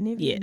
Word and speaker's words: Nivea: Nivea. That's how you say her name, right? – Nivea.